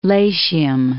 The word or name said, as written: Latium